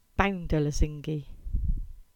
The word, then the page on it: Lesingey Lane: Cornish